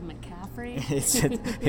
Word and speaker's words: McCaffrey: Does his name start with a C and end with a McCaffrey? – it rhymes with Pick-Affrey.